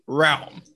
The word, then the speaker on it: realm: It's supposed to be realm. – Realm. – Realm. – Realm. You were saying realm. It's just realm. One syllable.